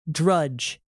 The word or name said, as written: drudge